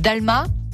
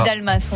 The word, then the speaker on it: Dalmas: C'est rue Louis Dalma. – C'est ça. Dalmas, on dit. Dalmas, on prononce toutes les lettres.